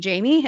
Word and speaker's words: Jaime: So she spelled it J-A-I-M-E because that means I love you in French. And now everyone thinks I'm Jaime.